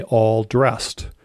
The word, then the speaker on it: all dressed: You just have to say all dressed. Although it's basically pronounced as one word without the D at the end, all dress.